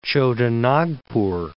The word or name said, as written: Chota Nagpur